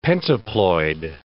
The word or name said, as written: pentaploid